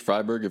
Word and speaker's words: Freiburg: Yeah, so Freiburg if you're speaking French, Freiburg if you're speaking German, which people do both of here. So however you pronounce it, it's probably fine.